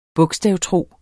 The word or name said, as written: bogstavtro